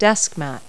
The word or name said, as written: desk mat